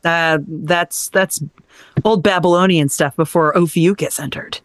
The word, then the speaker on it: Ophiuchus: Ophiuchus.